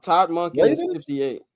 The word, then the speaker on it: Monken: It's Monken.